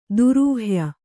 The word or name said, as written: ದುರೂಹ್ಯ